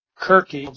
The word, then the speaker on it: Kirke: Kirke, R-E, but it's hard C.